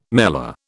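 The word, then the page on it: mêlée: British English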